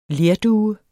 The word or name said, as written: lerdue